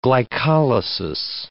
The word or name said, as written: glycolysis